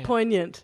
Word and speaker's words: poignant: Poignant, you do. You think it's poignant? – Poignant. – Do you? – Well, that's the only word I know that's got a silent G.